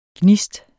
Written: gnist

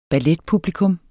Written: ballettoman

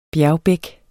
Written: bjergbæk

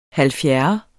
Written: halvfjerde

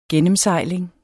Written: gennemsejling

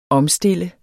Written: omstille

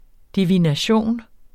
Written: DIVINATIONERNES

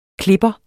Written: klipper

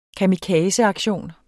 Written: kamikazeaktion

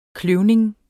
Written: kløvning

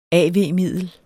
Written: av-middel

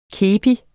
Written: kepi

